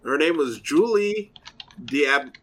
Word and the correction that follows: D'Aubigny: Hold on. Julie D'Aubigny.